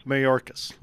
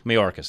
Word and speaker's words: Mayorkas: Alejandro Mayorkas. – Mayorkas. There we go. – Is that right, sir? – It is.